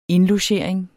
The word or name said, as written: indlogering